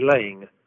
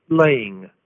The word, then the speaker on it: Laing: Hi, my name is Harry Laing, and it's pronounced like this, laying, like hens do with eggs.